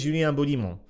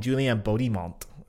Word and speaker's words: Julien Beaudiment: I know it makes me seem like a wanker, but his name is Julien Beaudiment. Okay, I'm not gonna call him Julian Bodimont.